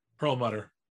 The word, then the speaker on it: Perlmutter: Perm Plumber. What is it? – Perlmutter. – Perlmutter.